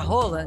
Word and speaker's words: Haaland: Lembra que a gente falou sobre a pronúncia do nome do Haaland, que era Roland? – Aham.